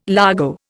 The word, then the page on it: largo: Indian